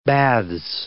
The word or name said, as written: baths